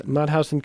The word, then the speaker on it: Mauthausen: Mauthausen. – Mauthausen concentration camp.